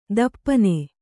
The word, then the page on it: ದಪ್ಪನೆ: dappane